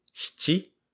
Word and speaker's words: shichi: When we counted, we said ichi, ni, san, shi, where shi is 4, then go, roku, shichi, where shichi is 7. So 4 and 7 in this example are shi and shichi.